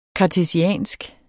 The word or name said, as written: kartesiansk